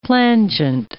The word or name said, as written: plangent